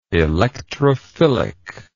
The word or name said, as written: electrophilically